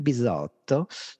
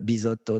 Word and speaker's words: Bisotto: Mais euh, donc, du coup, je l'avais signé Guillermo de Bisotto euh, parce que ben, séduction, forcément, parce que Guillermo, trois syllabes, Bisotto, trois syllabes, le 10, c'est le miroir du coaching.